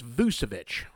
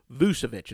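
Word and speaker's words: Vučević: Nikolai Vuvacek is questionable and for the Magic, but they are 3 and 1 without him going under 3 and 1 that's Vucevic. I'm sorry, is how you actually pronounce his name. Yes, I do watch NBA games. Vucevic is how you pronounce it.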